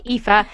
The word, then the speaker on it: Aoife: Anyone like wondering how to pronounce them, because written down, like, you know, phonetically, they do not look like that because they're Irish names. So it's like Aoife, that's spelled A-O-I-F-E, but it's Aoife, yeah, she's Aoife Bell, and then Tig.